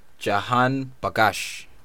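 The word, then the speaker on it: Jahanbakhsh: Wow, first one kind of sounds like Al Jazeera, even though it's not. Ali Reza Jahabakansh. – There we go.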